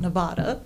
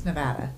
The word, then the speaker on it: Nevada: She's from California, but she says Nevada right. – It's not Nevada. – It's Nevada. – Yeah, see, she says it right.